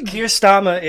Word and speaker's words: Keir Starmer: Keir Starmer is how the Brits pronounce it. It's Keir Starmer.